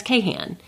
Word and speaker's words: Kahan: And so I look at K-A-H-A-N, and I'm like, oh, that's Cahan. But that's apparently the correct pronunciation.